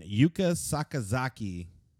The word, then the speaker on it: Yuka Sakazaki: And then Yuka Sakazaki.